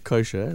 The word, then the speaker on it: Cosher: no, look, my real name's Colin Sher, and if you take the Lin out, you spell it with a C, you get Kosher, which I'm also Jewish.